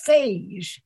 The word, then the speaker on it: phage: I learned that the correct way to pronounce that is phage, and their bacteriological phage are viruses that have existed in a war with bacteria since the beginning.